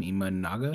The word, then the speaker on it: Imanaga: Imanaga. – For Imanaga, it was the opposite.